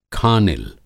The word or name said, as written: खानिल